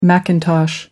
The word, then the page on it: macintosh: American English